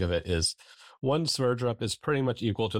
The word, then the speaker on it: Sverdrup: How do you say that? – Sverdrup. – Sverdrup. – Well, I think that's right.